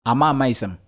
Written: AMAMAISIM

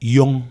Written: Yong

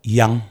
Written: Yang

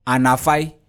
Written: ANAFAI

